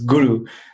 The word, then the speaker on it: guru: In Sanskrit, the word guru, like everyone's heard the word guru, which is pronounced totally incorrect, it's guru.